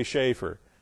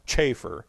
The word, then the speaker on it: Chafer: I mean, I lived and breathed Dr. Lewis Speary Schaeffer, or as his name properly would be pronounced, Chafer.